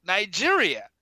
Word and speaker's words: Nigeria: England got the area called Nigeria, pronounced more like a British word.